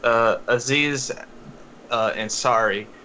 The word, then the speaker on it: Aziz Ansari: It's basically the story by Aziri Inari, or Aziz? Let me not butcher his name. Hang on. Real quick. Aziz Ansari.